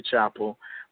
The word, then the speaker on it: Chapple: And, you know, on Facebook, I'm Andre Chapel. My last name is spelled C-H-Apple, C-H-A-P-P-L-E.